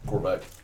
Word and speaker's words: Korvac: Yeah, Korvac.